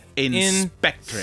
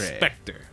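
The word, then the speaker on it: In/Spectre: Now spell it. I-N/ / S-P-E-C-T-R-E. – Inspector. – Inspector. Like a ghost. – Like a specter.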